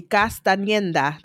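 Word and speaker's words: Castañeda: Well, it's an N there, so it would be Castaneda. That's how you say his name.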